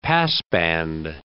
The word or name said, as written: passband